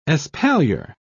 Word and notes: espalier: British English

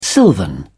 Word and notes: silvan: British English

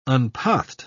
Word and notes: unpathed: British English

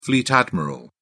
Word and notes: fleet admiral: British English

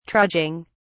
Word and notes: trudging: British English

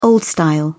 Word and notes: old style: British English

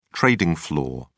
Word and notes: trading floor: British English

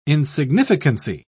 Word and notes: insignificancy: British English

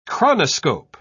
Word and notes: chronoscope: British English

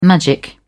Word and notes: magic: British English

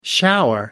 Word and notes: shower: American English

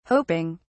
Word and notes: hoping: British English

